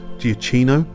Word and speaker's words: Giacchino: And then I'm going to end it up with the most recent iteration of Batman, with Robert Pattinson starring as Batman and Michael Giacchino, I believe is the correct pronunciation of his name, his theme to that film as well.